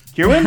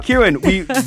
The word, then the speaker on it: Kirwan: Kirwan? – Hi, Kirwan, yep. – Kirwan, Mike and I never pronounce names right.